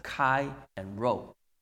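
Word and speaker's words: Chi and Rho (ΧΡ): 那呢他说这个符号呢就是希列文前面这个基督那两个字母，那我们就看到是 XP，其实呢希腊文应该是 pronounce as Chi and Rho。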